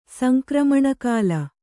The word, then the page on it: ಸಂಕ್ರಮಣಕಾಲ: Kannada